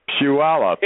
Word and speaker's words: Puyallup: It's a Puyallup. – Puyallup, okay.